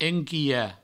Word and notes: enquilhar: enquiha; provençal

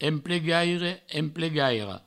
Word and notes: emplegaire: emplegaira; emplegairo

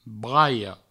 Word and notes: braias: braio

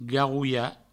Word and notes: garrolha: garrouio

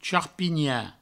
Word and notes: charpinhar: charpigna; provençal

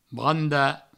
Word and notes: brandar: branda

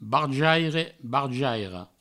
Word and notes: barjaire, barjaira: barjaire, barjairo; Provençal

